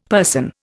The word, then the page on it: person: Indian